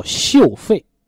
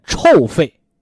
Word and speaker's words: 臭肺: 这第一魄叫嗅肺，是吧？也念臭肺，啊，我们中国人是很文雅的，是吧？所以把臭字呢读作嗅，啊，你包括现在你跟谁说你这病腋臭，你狐臭，你这谁听着都很什么呢？